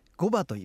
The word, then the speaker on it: 後場: うん、ちなみに株式を売買できる時間というのは午前と午後の 2 回あるんですけれども、午前中の立ち会い時間を前の場と書いて前場って、午後を後ろの場と書いて後場と言います。